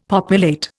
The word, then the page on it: populate: Indian